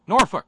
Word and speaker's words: Norfolk: We don't smoke. We don't drink. Norfolk.